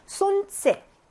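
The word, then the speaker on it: 손세 (солнце): 손세 L 발음 묵음이죠.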